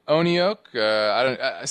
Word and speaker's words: ONEOK: We own Oneoke. Some people say I'm saying it wrong. Maybe I am. O-N-E-O-K is how you spell it. O-K-E is the symbol.